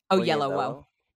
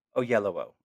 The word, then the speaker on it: Oyelowo: How do you say it? – Oyelowo. – Oyelowo.